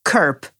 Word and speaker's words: curb: I oprócz control mamy jeszcze cudowny czasownik, który, uwaga, pisze się przez C, ale wymawia się curb.